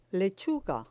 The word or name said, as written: lechuga